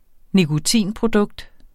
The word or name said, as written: nikotinprodukt